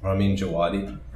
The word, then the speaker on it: Ramin Djawadi: She had the scene set to the best music on the show, which was the Ramin Djawadi? How do you say it? Oh, Ramin Djawadi.